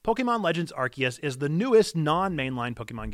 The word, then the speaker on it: Arceus: It's Arceus, it's Arceus, it's Arceus, it's Arceus.